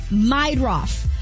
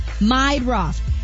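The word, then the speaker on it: Meidroth: Chase Myrdah? The White Sox guy? – Meidroff? – How do you say that? – Meidroff. – Meidroff.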